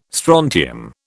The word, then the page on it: strontium: British English